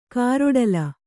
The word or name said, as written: ಕಾರೊಡಲು